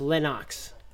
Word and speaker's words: Lennox: His name is actually pronounced Lennox. The, the Ox, Ox baby, yeah.